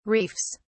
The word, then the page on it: reefs: British English